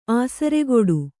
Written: ಆಸರಗೇಡಿ

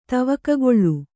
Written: ತವಕಗೊಳು

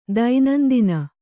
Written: ದೈನಂದಿನ

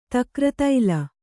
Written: ತಕ್ರತೈಲ

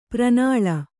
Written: ಪ್ರಣಾಳ

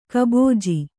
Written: ಕುಬುಜ

